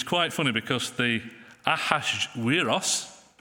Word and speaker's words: Ahasuerus: The Hebrew name, which we had read, was Ahasuerus. Ahasuerus. Apparently that's how it's pronounced.